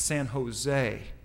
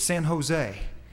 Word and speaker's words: San Jose: He says, "Well, he says normally over in California they don't pronounce the J's like that. It's a H sound. It's San Jose, right, Julio? It's San Jose."